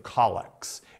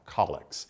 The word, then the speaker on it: collects: and in it there's a series of collects. It's spelled like collect, but it's pronounced collects.